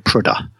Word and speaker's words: Prudhoe: It's spelled Prudhoe. They call it Prudha.